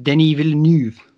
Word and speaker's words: Villeneuve: If you wouldn't have told me it was Alex Garland, I would have thought it was by, I can't pronounce his name, Denis Villeneuve, the guy who did Blade Runner. – Oh, yeah, Denis Villeneuve.